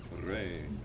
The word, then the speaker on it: regni: Regni. – Right, so there's always, you're always going to elongate the stressed syllable.